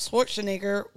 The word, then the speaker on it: Schwarzenegger: Being out in California, Arnold Schwarzenegger was, did I say that right? – Yeah.